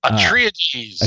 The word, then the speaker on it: Atreides: But coming out next week from Boom Studios, we have Dune, House of Atreides. I think is how you say it. – Atreides.